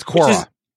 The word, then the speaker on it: Quora: I think it's pronounced Quora. – That's Quora.